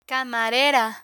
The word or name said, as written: camarera